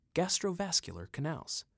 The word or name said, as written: Gastrovascular Canals